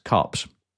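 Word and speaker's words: KuPS: The last one, if I do pronounce this correctly, I have done my homework on this, and I believe it is actually pronounced Cups.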